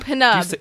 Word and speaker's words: Pnub: Pnub. – Do you pronounce the P? It's P-N-U-B or whatever. Nub? – Pnub. – Pnub.